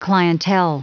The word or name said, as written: clientele